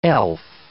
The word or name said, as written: elf